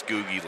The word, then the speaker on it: Googie: By the way, I think he pronounces his name Googie. Not. It's not a soft G. It's a hard G. I think it's Googie, like Google.